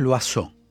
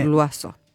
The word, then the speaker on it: Loiseau: Pronunciado es loazó. Se escribe loiseau. – Ahí está. Punto miel.loiseau. – Bien. – Bien. – Tiene Luazo.